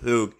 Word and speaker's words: Hug: Marcel, I think it's Hug. – Yeah, I think it's spelled Hug, but I believe you're right with Hug.